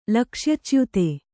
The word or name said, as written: ಲಕ್ಷ್ಯಚ್ಯುತ